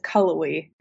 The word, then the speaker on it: Cullowhee: We always have people either calling it Cullowhee or some variation of that, but it's Cullowhee.